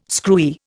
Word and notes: screwy: Indian

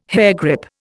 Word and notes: hairgrip: Indian

